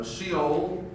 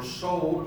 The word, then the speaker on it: Sheol: In Greek and in Hebrew, you read the Old Testament, you'll see the word Sheol or Sheol, depending on how you want to pronounce that, which simply means grave or death or place.